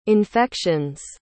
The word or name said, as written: infections